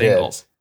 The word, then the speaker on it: Ingles: I think at the beginning of the segment, I said it right. Ingles. It's just Ingles.